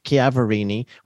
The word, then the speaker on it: Chiaverini: She pronounces it Chiaverini, which I hadn't realized.